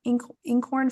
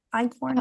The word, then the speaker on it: einkorn: I don't even know how to say the word inkhorn flour. Einkorn. – Oh, yes, Icorn.